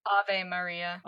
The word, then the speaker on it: Ave Maria: So anyway, Faye walks into the opera, and it's playing like this guy's doing a really solid rendition of, is it pronounced Ave Maria? I've heard it before. – Ave. – It's Ave Maria.